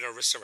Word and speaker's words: ERISA: ERISA.